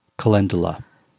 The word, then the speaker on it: calendula: Calendula.